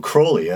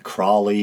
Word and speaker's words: Crowley: So Crowley, a lot of people pronounce it Crawley, but I believe Crowley is the proper pronunciation that he himself used.